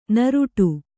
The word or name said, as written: ನೀರಾಟ